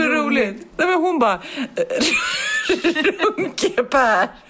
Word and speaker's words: Runkeeper: Och då insåg jag att Runkeper uttalas inte Runkeper utan Runkeper. Runkeper, Runkeper.